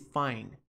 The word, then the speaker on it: find: Que verbo que é esse find? Find significa encontrar. Encontrar em inglês. Find. Find. Reparem na questão da pronúncia, tá? Esse D no final do verbo não é pronunciado forte. Ele é uma pronúncia silenciosa.